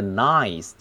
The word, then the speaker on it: nice: 那个 nice 要注意那个 i 的发音是双元音，又是大嘴音，就是 nice。